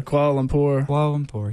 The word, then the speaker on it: Kuala Lumpur: Kuala Lumpur.